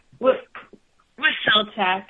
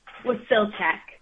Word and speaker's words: Wasilczuk: Wasilczuk, is that it? – Wasilczuk. I know it's a tricky one. – Give it to us one more time, please, ma'am. – Wasilczuk.